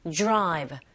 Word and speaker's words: drive: 자, 이때 드라이브로 발음하지 마시고 drive, drive. D sound, R sound 같이 붙어 있을 때 약간 J에 가깝게 소리가 들리거든요. Drive.